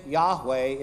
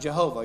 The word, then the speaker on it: Yahweh: So now most people think Yahweh is actually the better way to say the word, but for a while people thought that it should be pronounced Jehovah. You're like, how could they look at the same thing and get Jehovah and Yahweh?